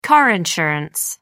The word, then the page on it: car insurance: American English